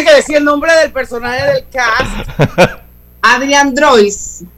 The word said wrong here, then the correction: Brody: Adrian Brody.